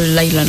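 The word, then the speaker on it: Lyla: Pero se escribe Lila, pero sí lo pronuncia como un poquito Laila, ¿no? – Sí, es que va con Y. Laila.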